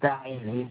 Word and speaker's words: Diane: My first name is Diane. It's spelled capital D-I-A-N-E.